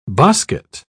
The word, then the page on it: bosket: British English